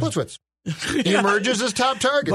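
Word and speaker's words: Plitzuweit: If you say Plitzwit real fast, you can't mispronounce it. Well, Plitzwit.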